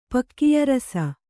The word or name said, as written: ಪಕ್ಕಿಯರಸ